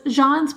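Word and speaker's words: Jeanne: It's J-E-A-N-N-E, but it's pronounced Jean.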